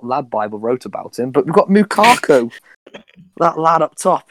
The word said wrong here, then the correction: Lukaku: Who the fuck's Lukaku? Do you mean Lukaku? – I don't know.